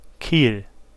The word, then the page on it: Kiel: /kiːl/